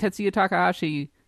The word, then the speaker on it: Tetsuya Takahashi: Because if there was one thing that Tetsuya Takahashi did, and for, I think I got the name right this time.